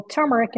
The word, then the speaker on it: turmeric: It's turmeric, but I always say turmeric because when you look at it, it has an R someplace where it shouldn't be, which really drives me crazy.